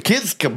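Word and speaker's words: Kiszka: It's Josh, Jake, and Sam Kiska. – Kiska, yeah. – Okay. – Yeah. – Which is why they went by Van Fleet, Greta Van Fleet. Because the Kiska brothers doesn't have the same...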